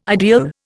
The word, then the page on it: ideal: Indian